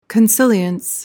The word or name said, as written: consilience